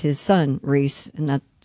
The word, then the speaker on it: Rhys: Does he pronounce it Rhys? – Rhys. – Does he? I don't know. – Oh, it is Rhys.